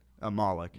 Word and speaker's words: Malek: It was like weird, but Thrillist put out this article where they kind of bashed Rami Malek. That's how you pronounce it, right? – Malek. – Malek.